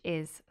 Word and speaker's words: hai: I, spelt H-A-I, means you have. The H is silent.